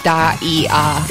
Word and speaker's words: Daia: Also, I'm looking at the Korean here, and it looks like their name is pronounced Daia. – Daia. Daia. – So like diamond.